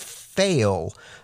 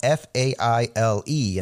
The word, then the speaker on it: Faile: It's a character called Fail. Her name is spelled F-A-I-L-E, and I've always just read it as Fail. Some people might say Fail-E, but this is a key character that we're really surprised to see this soon in the story.